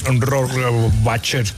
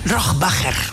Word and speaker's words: Rohrbacher: Bueno, ¿cómo se pronuncia Alba Rohrbacher Cochet? – Rohrbacher. – Rohrbacher.